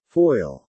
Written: foil

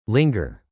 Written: linger

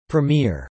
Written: premiere

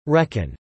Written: reckon

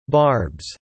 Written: barbs

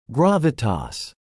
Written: gravitas

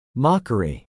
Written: mockery

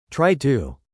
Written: try to

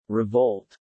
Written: revolt

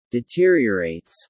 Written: deteriorates

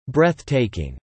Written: breath-taking

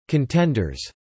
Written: contenders

